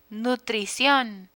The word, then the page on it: nutricion: spanish